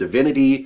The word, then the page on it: divinity: American English